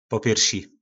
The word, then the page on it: popjyrsi: po nasimu